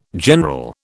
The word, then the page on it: general: British English